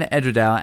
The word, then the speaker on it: Edradour: Well, I've always called it Edradour, and I might be saying it wrong, but you got any suggestions? – Edradour. – Edradour. – Edradour. – Yeah, I'm going to call it Edradour from now on.